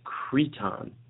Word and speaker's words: Crichton: So, apparently, we've been saying Crichton's name wrong. – What? – It's Creton. Creton.